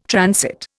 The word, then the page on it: transit: Indian